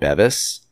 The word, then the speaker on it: Bevis: But so we're at 1616 Beavis Street. Okay. Some locals call it Bevis.